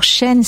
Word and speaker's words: Shane: Alors, Chaîne, c'est S-H-A-N-E.